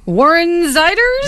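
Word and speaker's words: Warren Zeiders: Warren Ziders? – Yeah, yes.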